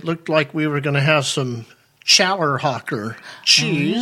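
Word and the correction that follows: Challerhocker: And I learned that it's actually called Hollerhocker. – Oh. – So the C is silent, see? – Yeah.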